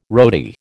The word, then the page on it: roadie: American English